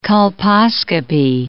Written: colposcopy

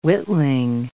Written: witling

